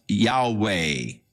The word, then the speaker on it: Yahweh: So the general consensus of opinion is that the name was to be pronounced Yahweh.